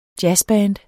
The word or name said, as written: jazzband